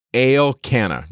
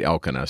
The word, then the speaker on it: Elkanah: And we're introduced to this guy, and his name is Elkanah. Now, the English is just going to read Elkanah. So you can just say Elkanah, but it's pronounced at least the way the Masoretes put it out there, Elkanah.